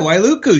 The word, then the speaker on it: Wailuku: Yeah, Wailuku.